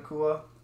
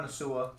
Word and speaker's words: Nacua: Puka uh, Nacua. It's not Nasua.